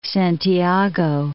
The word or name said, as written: Santiago